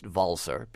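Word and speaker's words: Walser: Al Walzer, more properly pronounced Walzer, because he's from Liechtenstein, a German-speaking country in Central Europe, is nominated in the same category for best dance recording, mm-hmm, as, you know, superstars like Skrillex and Swedish House Mafia.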